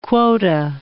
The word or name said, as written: quota